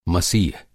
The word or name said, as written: मसीह